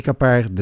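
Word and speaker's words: Keppard: Capard op zijn Frans uitgesproken.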